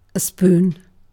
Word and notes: spoon: British English

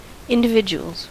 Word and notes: individuals: American English

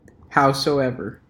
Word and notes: howsoever: American English

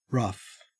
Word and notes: rough: Australian English

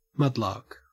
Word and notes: mudlark: Australian English

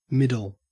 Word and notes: middle: Australian English

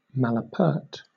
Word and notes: malapert: British English

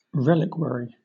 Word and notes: reliquary: British English

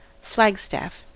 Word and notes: flagstaff: American English

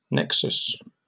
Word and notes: nexus: British English

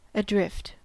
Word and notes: adrift: American English

